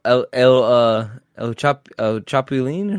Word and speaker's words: El Chapulín: Oh, El Chapulín, is how you say that? – Kinda, yeah, pretty much.